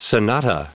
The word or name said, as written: sonata